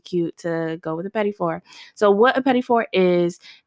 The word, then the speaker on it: petit four: But it's a pastry word, pétifort. So pétifort is spelled P-E-T-I-T-F-O-U-R. And it's something that's used across the culinary world, but definitely in pastry.